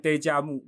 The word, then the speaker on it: déjà vu: 有点是那个有点 déjà vu 嘛，有发音对不对？欸、可是对，没错啊。